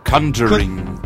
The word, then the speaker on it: Conjuring: Äh, wie spricht man das aus? – Conjuring. – Conjuring? In Conjuring. Ja.